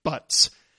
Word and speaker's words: Butz: Now, there is a wrinkle in the case of Professor Arthur Butts. His last name, incidentally, is spelled B-U-T-Z.